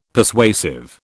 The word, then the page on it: Persuasive: British English